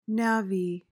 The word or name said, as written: navvy